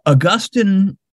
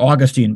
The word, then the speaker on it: Augustine: So Augustine, or Augustine, by the way, you can pronounce the name either way. Augustine, Augustine, doesn't matter.